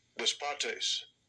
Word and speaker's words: despotes: So here, Lord is in Greek is the word despotes, I guess. But no, you know, rather than guess, it turns out there's a tool. This is how it's pronounced in Greek. Lord, Lord. – Strong's G 1203. Despotes.